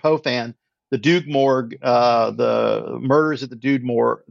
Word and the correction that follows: Rue Morgue: the, you never say this right, at the Rue Morgue.